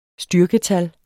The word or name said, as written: styrketal